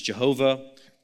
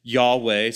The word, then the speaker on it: Yahweh: You might have heard that pronounced as Jehovah. It's a mispronunciation, probably best pronounced something like Yahweh.